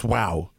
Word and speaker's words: Guau: That's a G-U-A-U. It's pronounced wow. But it's G-U-E. – Guau is how dogs, it's like bow wow in Spanish.